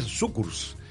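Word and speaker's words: Cukurs: ¿Podemos repasar un poco quién fue Kukurs? Porque me cuesta mucho el apellido, ¿sabes? – Kukurs. En realidad se pronuncia, en letón se pronuncia Sukurs.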